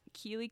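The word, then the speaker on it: Keeley: You can follow me at Keely K. Norton. I will spell it for you because my name is weird. K-E-E-L-E-Y-K-N-O-R-T-O-N.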